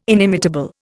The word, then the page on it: inimitable: Indian